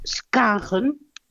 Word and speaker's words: Schagen: Spreek je Schagen uit als Skagen? Ja, ze knikken allemaal ja.